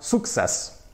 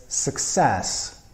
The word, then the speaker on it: success: If you're Polish watching right now, you've probably heard it yourself or said it yourself, sukces, right? The stress goes on that first syllable, sukces, but it's success, success. Put a nice strong intonation, a nice stress on the second syllable, success.